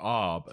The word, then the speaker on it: Av: At sunset, July 18th, 2023, the month of Av, or Ab as it's pronounced, will begin.